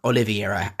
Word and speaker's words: Oliveira: Carlos Oliveira, or Oliveira, as he's actually pronounced.